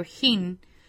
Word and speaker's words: hin: Hin is pronounced in Hebrew hin, and it means liquid measure.